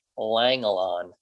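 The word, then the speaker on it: Langelaan: George Langelon. That's how you pronounce his name.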